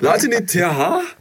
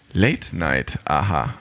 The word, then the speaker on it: Late Night Aha: Latinitier? Latinitier? – Latinitier? – Latinitier? – ähm, der Titel wird korrekterweise Late Night Aha ausgesprochen.